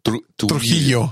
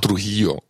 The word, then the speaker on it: Trujillo: Trujillo. – Trujillo.